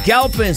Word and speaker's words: Galpin: Galpin se escribe G-A-L-P-I-N. Así como lo voy a decir en español, Galpin. Así como se escucha, Galpin.